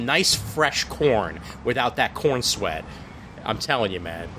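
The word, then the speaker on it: Corenswet: Cornsweat, is that his name? – Cornsweat. Not corn and sweat, but it's pronounced kind of like that same.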